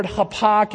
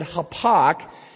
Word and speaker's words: hapak: So in your Bibles, if you want to write down or in your outline, the word hapak, it's the transliteration is H-A-P-A. It would be K would be the best way to pronounce that hapak.